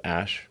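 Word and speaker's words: Æ: I mean, it's just X, the letter X. The AE is like pronounced ash. And then A12 is my contribution.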